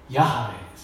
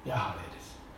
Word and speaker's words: ヤハウェ: そして、私の主人に賜った主の御言葉、この主の御言葉の主というのがヤハウェですね。ヤハウェです。当然ユダヤ人はこれを発音するとき 2 つともアドナイというふうに発音しますけれども、筆記の仕方は違うわけですね。